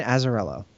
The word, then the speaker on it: Azzarello: And so Azzarello, I think I'm saying his name right. – His name is Brian Azzarello. – Brian Azzarello, thank you.